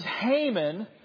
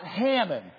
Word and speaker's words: Haman: This is Haman, not Hammon, okay? Don't call me Haman. Don't call him Hammond.